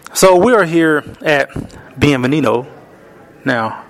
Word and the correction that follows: Bienvenidos: Am I saying that right? – Bienvenidos.